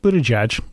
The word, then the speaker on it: Buttigieg: Mayor Pete, Buttigieg. – Is that how you say it? – Yeah, it's Pete Buttigieg, the mayor of the hamlet of South Bend.